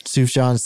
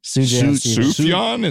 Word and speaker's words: Sufjan: How do you say his name? Sufjan Stevens. – Sufjan. – Sufjan is how I usually hear it. – Oh, okay. Sufjan.